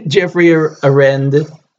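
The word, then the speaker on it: Geoffrey Arend: Yes, Jeffrey Arend. That's how you say his name.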